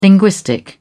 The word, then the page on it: linguistic: British English